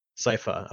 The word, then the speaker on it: Sifa: I mean, obviously, you know, like the main protagonists and stuff with Trevor and Sifus. Am I saying that right? – Sifa. – Okay. Yeah. I always add a letter for some reason.